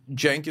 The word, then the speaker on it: Cenk: I mean, Cenk is spelled C-E-N-K, but the C is pronounced like a J. It's not pronounceable. It's not possible. No one knows that in Turkish, C is pronounced as a J.